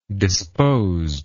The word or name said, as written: disposed